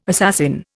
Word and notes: assassin: Indian

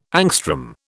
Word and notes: angstrom: British English

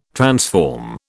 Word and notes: transform: British English

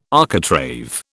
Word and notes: architrave: British English

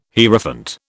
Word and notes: Hierophant: British English

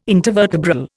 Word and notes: intervertebral: Indian

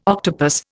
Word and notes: octopus: Indian

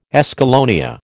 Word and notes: escallonia: American English

